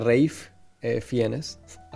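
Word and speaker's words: Ralph Fiennes: según él, se pronuncia Rafe Fiennes a pesar de que se escribe Ralph.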